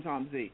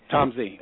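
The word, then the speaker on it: Tom Zé: Z, we call him Tom Z. – Tom Z.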